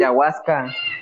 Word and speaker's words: ayahuasca: Entonces le dan a este aguayas, aguayas, ¿cómo se dice, güey? Ayahuasca. Ayahuasca, güey y dice que cambió totalmente su perspectiva de todo, que para él una piedra tiene vida, güey.